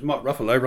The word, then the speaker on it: Ruffalo: And also, of course, another Julia Donaldson, because Mark Ruffalo rhymes with Gruffalo.